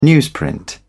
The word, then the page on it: newsprint: British English